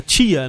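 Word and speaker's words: Tchia: Eu falei o nome do jogo, é Tia, né? É T-C-H-I-A.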